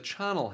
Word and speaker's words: charnel: He's all like, He must have gone downstairs into the charnel house. – Yes. And he's like, let me Google that. Is it charnel or carnel? – Hang on. UK pronunciation. Charnel.